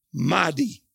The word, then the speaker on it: Made: And so the leader of the village is a guy named Mahdi. It's spelled M-A-D-E, but it's Mahdi.